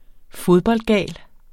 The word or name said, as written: fodboldgal